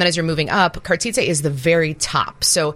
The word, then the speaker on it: Cartizze: So it's Cartizzi? Mm-hmm. Is that how you would pronounce it? Cartizze.